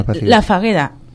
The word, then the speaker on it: Fageda: Hay muchos ejemplos, en España, por ejemplo, La Falleda, que bueno, es dedicado, está, digamos, La Fagueda. – Sí, bueno, pues con bueno, decimos en castellano, Fageda, se escribe, ¿no? Con G, La Fagueda, en Gerona, que es como una empresa que han montado exclusivamente para personas con problemas de salud mental y que funciona perfectamente.